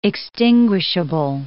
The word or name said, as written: extinguishable